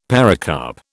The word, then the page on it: pericarp: British English